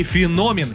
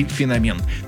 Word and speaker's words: феномен: Ну и феномен. Именно так следует произносить это слово, хотя некоторые версии допускают иногда и феномен.